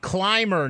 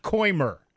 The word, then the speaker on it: Clymer: Clymer, not Coimer.